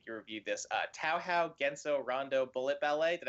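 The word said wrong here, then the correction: Touhou: Toho, okay.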